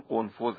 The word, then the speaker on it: قنفذ: البته کلمه اسم اون فردی رو هم که میگن ضربه زده رو اشتباه تلفظ کردم، قنفذ هست با قاف و پایانش هم زه دالزال.